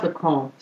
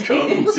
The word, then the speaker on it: Comté: It's pronounced Le Comte. – Mm-hmm. – It's French. – C-O-M-T-E? – Yeah. – Le Comte, yeah, like the Count.